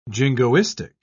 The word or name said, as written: jingoistic